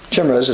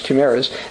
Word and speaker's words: chimeras: Such mixed species embryos are known as uh, chimeras, or chimeras is, chimeras as I would say from Michigan, but it's actually pronounced chimeras, named for the fire-breathing creature from Greek mythology that is part lion, part goat, and part snake.